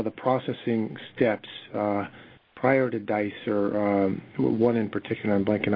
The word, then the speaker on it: Dicer: D-I-C-E-R. – D-I-C-E-R.